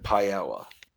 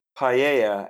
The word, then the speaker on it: paella: Number 49, paella, and you all can play along at home by looking at the recipe on your computer screens. – The double L is pronounced as a Y sound.